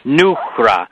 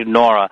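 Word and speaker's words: Noohra: That's N-O-O-H-R-A. It's really pronounced Nuhra, but it's too hard for people to say, so we just shorten it to Nora. But it's not spelled like the name Nora. It's N-O-O-H-R-A, Nuhra, and it means light. That's the Aramaic word for light.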